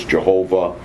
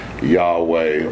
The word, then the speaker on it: Yahweh: So, we know it only by the four consonants which it contains, which are usually transliterated into English YHWH, and has been variously pronounced as Jehovah, Yahweh, or Yahweh.